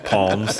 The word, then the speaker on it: Psalms: We pronounce them palms. – So they did that one. – Yes was silent. – Yeah. – In these parts. – Well, it looks silent to me.